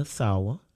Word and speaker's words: Sawa: But today we learned about Becca Sawa. Did I say it right? – What? – Your last name? – Sawa. Yeah. – Sawa. Okay. Let me say that again.